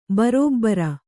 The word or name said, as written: ಭರಭರ